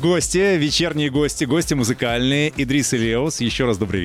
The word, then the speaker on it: Idris: У нас Идрис и Леус. Правильно я ударение расставил? Все правильно. Да, да, да.